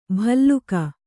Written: ಭಲ್ಲೂಕ

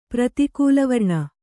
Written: ಪ್ರತಿಕೂಲವರ್ಣ

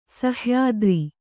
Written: ಸಹ್ಯಾದ್ರಿ

